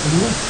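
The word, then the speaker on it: Emunah: This goes along with the Emuah. Did I say that right? – Yeah, that's perfect. – Emuah.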